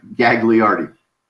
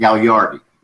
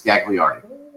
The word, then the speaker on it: Gagliardi: It's Italian, and the Italians would pronounce it Gagliardi, but it's Gagliardi.